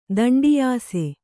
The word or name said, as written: ದಂಡಿಯಾಸೆ